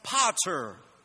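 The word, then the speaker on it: pater (πατήρ): Friends, the word father in the Greek is pronounced pater.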